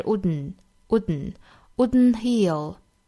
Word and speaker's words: wouldn't: 那么这里面的这个的同样也是不发音的，不要把它发成了 wouldn't，应该是 wouldn't heal。